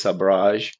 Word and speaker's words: Sabrage: They're both accepted, but if you want to use the correct version, it is Sabrage, which comes from French, sabre, which is, you know, saber.